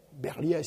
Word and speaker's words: Berliet: Berliet, c'est quand même l'entreprise, la grande entreprise, disons, de construction de camions. Et on prononce Berliet, et non pas comme certains Berliet. En français, ça prononce Berliet.